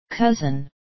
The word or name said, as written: cousin